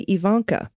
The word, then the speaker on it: Ivanka: It starts with a long E sound, not a long I sound. Okay, Ivanka.